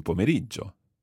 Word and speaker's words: pomeriggio: It's pomeriggio. – Pomeriggio. – And you could theoretically say buon pomeriggio. It's a masculine word, so we use buon. Buon pomeriggio.